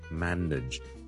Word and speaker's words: manage: 由于呢个字后面 N A G E 咧 nage 咧系串做 N A G E，好多人咧就会将佢读成咧 manage 嘅。咁但系事实上咧，我呢个字咧系读 manage nage nage nage，你当系好似 N I D G E 咁读。OK manage，